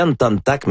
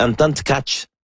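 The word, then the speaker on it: Anton Tkáč: Or, dans mon souvenir, c'est bel et bien Anton Tack et j'ai toujours pensé à Anton Tack et je me souviens que les commentateurs à l'époque disaient Anton Tack, mais c'est pas la façon de la prononcer. Il y a Yuraï Benko qui nous mentionne aujourd'hui qu'on prononce Anton, il dit prononcez bien vite en tchèque Anton Tkach.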